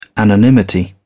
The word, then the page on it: anonymity: British English